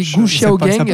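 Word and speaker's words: Gu Xiaogang: C'est un film chinois, un premier film de Gu Xiaogeng. Je pense que je prononce absolument très mal. On dit Xiao. pardon, oui, oui, Gu Xiao Gang.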